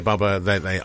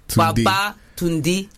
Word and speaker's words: Babatunde: Babatunde. – Tunde. So say it like you're saying come back or returned. – Baba Tunde. – Yeah. It's like a song.